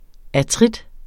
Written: artritis

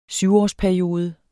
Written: syvårsperiode

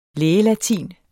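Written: lægelatin

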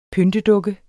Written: pyntedukke

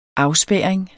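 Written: afspærring

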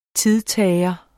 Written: TIDTAGERE

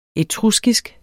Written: etruskisk

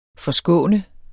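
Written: FORSKÅNER

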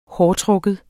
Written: hårtrukken

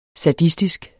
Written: sadistisk

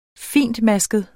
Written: fintmasket

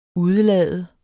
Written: udelade

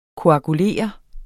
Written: koagulere

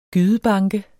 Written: gydebanke

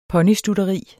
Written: ponystutteri

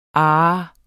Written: ah

